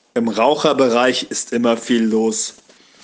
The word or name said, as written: Raucherbereich